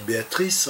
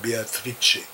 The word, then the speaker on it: Beatrice: En français, on dit Béatrice, en italien, on dit Beatrice. C'est ainsi qu'elle prononce son nom.